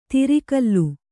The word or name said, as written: ದಾರೊಕ್ಕಲು